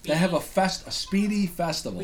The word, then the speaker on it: spiedie: And the Speedy is spelled S-P-I-E-D-I-E. – Yes. My pet peeve is when people spell it wrong, incorrectly. – Or say Spideys.